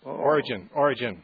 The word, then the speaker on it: Origen: Have you heard of a theologian, Orion, or I'm not sure how you pronounce it. – Origen. – Origen, yeah.